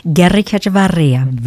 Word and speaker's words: Guerricaechevaria: Guerricaechevaria.